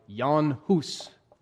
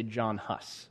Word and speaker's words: Jan Hus: Technically, it should be pronounced more like Jan Hus, but I'm just going to say John Huss.